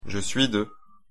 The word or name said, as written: je suis de